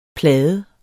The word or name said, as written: PLADERNES